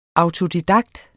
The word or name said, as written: autodidakt